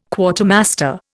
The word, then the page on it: Quartermaster: Indian